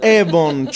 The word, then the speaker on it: Avon: Si dice Avon, ci sono anche stato di persona, si vede Stratford up of Avon.